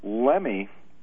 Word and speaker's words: Lemme: Raymond Lemme, actually. – Lemme, okay. – I understand it's pronounced.